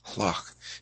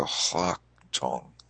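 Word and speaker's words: lhaktong: And many people have struggled with, like, how do you pronounce LH? And it's just like you sort of aspirate the L, if that means anything to you. Hlok. You actually pronounce it H-L. You go hlok tong.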